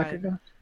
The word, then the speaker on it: Mechagon: Am I saying that right? – Yeah, Mechagon. Yeah, Mechagon.